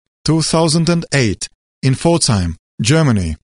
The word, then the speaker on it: Pforzheim: I have come tonight to your Pforzheim to deliver to you the translator.